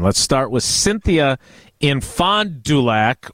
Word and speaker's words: Fond du Lac: There's no J in Fond Thank you.